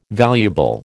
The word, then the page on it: valuable: American English